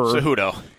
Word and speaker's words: Cejudo: So UFC star Henry Cejudo, is that how you pronounce Cejudo. – This guy? Cejudo? – Cejudo, yeah.